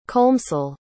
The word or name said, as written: colmcille